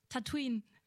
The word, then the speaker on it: Tatooine: Tatooine.